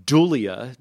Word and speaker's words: dulia: Secondly, would be dulia, just how it sounds. It is spelled D U L I A, like like the feminine name Julia, but with a D.